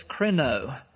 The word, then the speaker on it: krino: But the first word, so we'll know there's a difference, I'll pronounce this, krino. K-R-I-N-O is a Greek word, translated in the English Bible 88 times, judge.